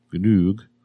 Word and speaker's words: Ganoog: So moving on from the game that I can't pronounce, Ganoog. Oh, thank you. – Jamazel. – Rhymes with Doug.